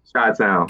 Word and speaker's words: Chi-town: Chi-town.